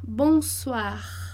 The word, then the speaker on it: bonsoir: O o e o i vai fazer o a. Bonsoir, bonsoir. É como se fosse suar, bonsoir.